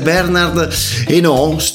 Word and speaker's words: Bernard Hinault: il ciclista francese Bernard Hinault, dovrebbe pronunciarsi così, non c'è la R moscia, quindi dovrebbe essere abbastanza esatta la pronuncia,